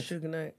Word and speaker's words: Suge Knight: But he gave him 1 million to kill Sujanite. Am I saying that properly? Suge Knight? – Suge Knight.